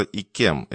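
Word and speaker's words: Yquem: Кроме того, минуту назад поступило сообщение о продаже бутылки «Шато», как его там, «Юкум». Это произносится «Икем», Эрик.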